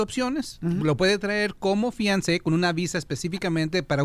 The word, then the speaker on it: Fiancé: Abogado, ¿cómo puede serle mi querida Leti para traer a su Fiancé, se dice así, ¿no? En inglés. – Es una palabra French.